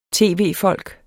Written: tv-folk

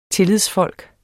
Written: tillidsfolk

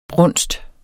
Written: brunsten

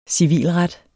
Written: civilret

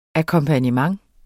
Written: akkompagnement

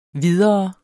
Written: videre

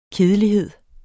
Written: kedelighed

